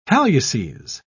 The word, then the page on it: halluces: British English